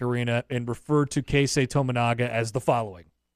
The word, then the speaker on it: Tominaga: Wouldn't you just say Keisei Tominaga? Maybe you would say Keisei. – Yeah, there is no reason in Year of Our Lord 2024 to mispronounce Tominaga. – Tominaga looks exactly how it looks.